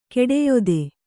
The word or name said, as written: ಕುಡಿಯಿತಿ